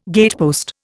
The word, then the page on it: gatepost: Indian